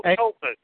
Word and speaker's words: Tulpas: Tuplas. T-U-P-L-A. – Oh, Tulpas. – Oh, I mispronounced it.